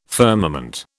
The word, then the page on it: firmament: British English